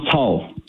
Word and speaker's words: Cao: Is it Pastor Cao? – Yeah, Pastor Don Cao.